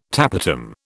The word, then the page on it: tapetum: British English